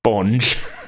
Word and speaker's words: Bonge: Bonge. Okay. How are you spelling that? – B-O-N-G-E. Bonge. – It's very close to bong, but okay.